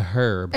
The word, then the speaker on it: herb: A, a fruit, B, a vegetable, C, a herb. – An herb. – An herb. – Herb. Herb. – Herb. – Herb. – Herb. – The H is silent.